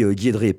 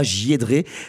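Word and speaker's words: JieDRe: Il me semble qu'on dit Guy Edré, pas J. Edré. Ça s'écrit J-I-E-D.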